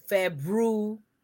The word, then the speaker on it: February: However, since it's the quote unquote recognized period, let me first start off by saying the month is called February. F-E-B-R-U-A-R-Y. It's not February. It's not February. I done heard all kinds of stuff for how you pronounce the month. It's spelled F-E-B-R-U-A-R-Y, and it's pronounced February. February. Okay? Don't say February, because that's not what it is. And so it's not a berry either, like February, February. No, F-E-B-R-U-A-R-Y, February. Make sure you pronounce all of the letters.